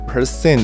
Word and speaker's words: percent: 最后提出一个小小建议，就是丰德在练 percent 的时候呢。重音其实念错了，发音不是像 person 一样。虽然大部分人都会念成跟 person 一样哦，因为我以前不知道的时候也是这样子念。